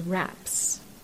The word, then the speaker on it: Raps: And the German pronunciation of those flowers is raps.